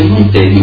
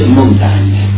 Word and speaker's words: Montaigne: perché in realtà Montaigne non si dice Montaigne ma si dice Montagne.